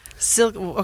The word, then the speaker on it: Silkeborg: And hopefully they're still, I haven't spoken to them in a little while, but I went to visit them in Silkeborg. Did yep. I say that right? – Nope. – Damn. – Silkeborg. – Okay, so there's no G. – No, Silkeborg. – Silkeborg. – Yeah. – Okay, closer.